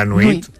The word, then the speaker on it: nuit: La nuit.